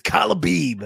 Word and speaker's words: Khabib: Khabib. It's Khabib.